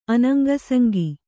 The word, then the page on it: ಅನಂಗಸಂಗಿ: Kannada